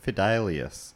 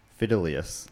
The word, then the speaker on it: fidelius: I remember on an earlier episode I once said, and I'd always pronounce it this way, Fortius quo fidelius. And I got a message from someone saying, that is not right. Fidelius.